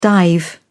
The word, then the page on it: dive: British English